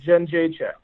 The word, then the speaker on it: Jędrzejczyk: A fellow Polak, I'll help you pronounce it, okay? Jędrzejczyk.